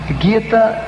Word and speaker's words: Gita: E se é Gita, se é Gita. Gita é a pronúncia porque vem de um livro indiano chamado Bhagavad Gita.